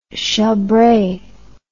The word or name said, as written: chambre